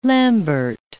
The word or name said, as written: lambert